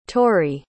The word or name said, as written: torry